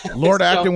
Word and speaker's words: Lord Acton: Lord Acton would be proud.